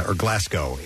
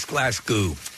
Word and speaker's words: Glasgow: Green Day continue on the Hella Mega Tour with Fall Out Boy and Weezer tomorrow in Glasgow in the UK. – It's Glasgow. – Glasgow. I'm sorry. I said that incorrectly.